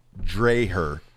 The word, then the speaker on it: Dreher: So I'm gonna say his name, Colin Taylor Dreher. – Yeah, it's spelled like Dr. Dre. Dreher.